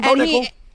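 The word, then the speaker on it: Bo Nickal: did like a 30-second wrestling with Bo Nickel. Am I saying that right? I hope I'm saying that. No, you got that one, Bo Nickel. – There you go, Bo Nickel.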